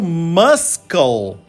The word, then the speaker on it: muscle: A pessoa lê aquele M-U-S-C-L-E e tasca-lhe o muscle. Não, infelizmente, você errou! Correta maneira de pronunciar é suprimindo o e dizendo muscle.